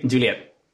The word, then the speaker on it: Juliet: Y tú desarrollaste algo que se llama Juliet, no sé si se pronuncia así, Juliet. – Sí, sí, Juliet.